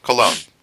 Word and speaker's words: Cologne: Cologne or Colin? – Cologne. – Oh, okay. – There's an accent on the second O.